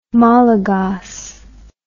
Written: malagas

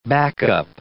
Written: backup

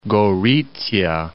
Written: Gorizia

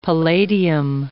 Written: palladium

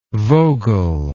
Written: vogel

